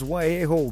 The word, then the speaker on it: Wahejo: It's spelled W-A-H-E-J-O. But of course, that is not an English word. But nonetheless, it is Waheho.